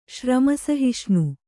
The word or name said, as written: ಶ್ರಮಸಹಿಷ್ಣು